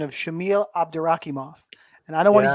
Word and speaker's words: Abdurakhimov: But Abdurakhimov.